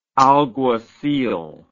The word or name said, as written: alguacil